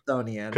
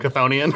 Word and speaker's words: Chthonian: So it's very the Empress of like Chthonian. How do you pronounce that word? – It has to be Chthonian. – Chthonian.